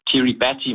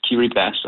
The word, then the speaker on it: Kiribati: Det er jo, det staves Kiribati, men det udtales Kiribati, og det er meget underudviklet.